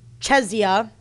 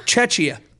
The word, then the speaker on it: Czechia: Czech Republic and Czechia, I didn't say it right, whatever. They refer to the same country. – Yes. – The Czech Republic is the official name. Wow, what is it? Czechia? – Czechia.